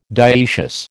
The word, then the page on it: dioecious: American English